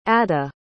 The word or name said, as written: adda